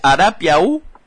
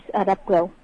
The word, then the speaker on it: Arapiaú: Ajá, y lo dijo bien Noelia, es Arapiaú. – Sí, es Arapiaú. – Arapiaú, bueno. – Una pronunciación más cerrada.